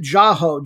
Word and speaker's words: Jahouh: But their all-around best player, and he's number one in the league in assists, is Ahmad, I'm going to pronounce it Jaho, J-A-H-O-U-H.